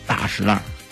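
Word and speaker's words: 大栅栏: 双儿化音，中间轻声一笔带过，所以就叫大石栏。 – 哦，哎，这个栅呢有两个读音，咱们查这个字典，一个叫栅，一个是山，一个读音念山。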